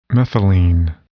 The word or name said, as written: methylene